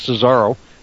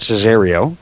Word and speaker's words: Cesaro: You noticed I didn't pronounce it Cesario. – Good job. – Thank you very much. I've got notes in front of me this time. And I noticed there's no I before the O.